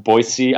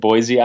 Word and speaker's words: Boise: One thing I feel bad about, there's a line in there about it should be pronounced Boise, Idaho, but we said Boise, Idaho, which is how people usually pronounce it, but that's wrong.